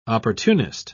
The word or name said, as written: opportunist